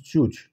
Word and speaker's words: Ya'juj: In formal Arabic, they don't have a letter for Ga, so they pronounce it as Ja. Then in Arabic, Gog becomes Juj.